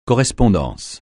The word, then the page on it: correspondance: francais